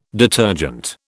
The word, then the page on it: detergent: British English